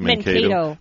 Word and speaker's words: Mankato: Mankato.